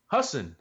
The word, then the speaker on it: Husson: Hassan. Husson versus Alfred State and Brockport versus Alfred, we mentioned.